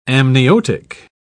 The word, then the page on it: amniotic: British English